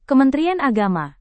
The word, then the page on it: kementerian agama: indonesian